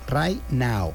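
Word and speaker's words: Rai Nao: Se me hizo curar el nombre porque pues se pronuncia como Right Now. – Ajá. – Pero en realidad es Right Now. – ¿Y cómo sale la letra A? – R-A-I, latina, N-A-O.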